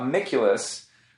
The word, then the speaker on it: Amiculus: But we're also here to talk to him about Amiculus, which was, I said it right.